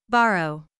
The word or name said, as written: borrow